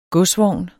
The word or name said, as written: godsvogn